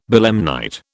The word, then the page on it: belemnite: British English